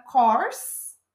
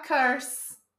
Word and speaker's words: course: Quando a gente fala a palavra course, que é curso, e o aluno tenta falar curse. E curse é maldição, né? Então, tem que ficar esperto com essa pronúncia. Course é curso, curse é a, mal, a maldição e cursed é o amaldiçoado.